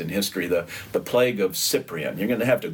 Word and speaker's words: Cyprian: Know that Cyprian, the name of this man, starts with a C, so it's a soft C. It's the plague of Cyprian.